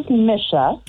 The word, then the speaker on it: Misha: Okay, well, I think you got my name wrong, but this is Misha. – Misha. I'm sorry. – Saying, like, it does not sound like Nissa.